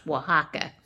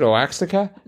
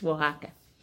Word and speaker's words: Oaxaca: It's pronounced Oaxaca. – Okay, that helps. – Yeah, it's in Mexico. – You sure they never pronounce it Oaxaca? Because that's fun to say. – No, it's Oaxaca. – So if I go there, okay, Oaxaca. – It starts like with a W, Oaxaca.